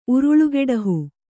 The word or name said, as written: ಉರುಳುಗೆಡಹು